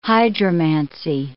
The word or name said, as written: hydromancy